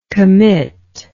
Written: committer

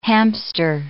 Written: hamster

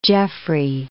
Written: Jeffrey

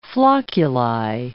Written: flocculi

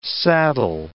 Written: saddle

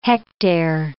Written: hectare

